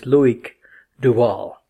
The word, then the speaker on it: Loïc Duval: And I had to ask him before the interview, what is the correct pronunciation? Because I hear so many variations. And he said it's Loïc Duval.